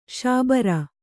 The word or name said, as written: ಶಾಬರ